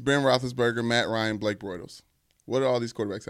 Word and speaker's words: Bortles: Bortles.